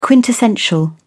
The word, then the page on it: quintessential: British English